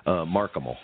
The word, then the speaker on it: Mark Hamill: Mark Hamill, I believe is how it's said.